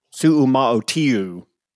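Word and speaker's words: Suamataia: Kingsley Su'uma'otiu from BYU. – I am giving you all kinds of props for that pronunciation. Lord knows that it was right, but it sounded was.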